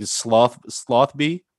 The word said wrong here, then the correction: Sotheby's: Um, slothbees. – What is it? Sotheby's. Sotheby's. Okay, there you go.